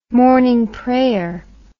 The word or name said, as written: morning prayer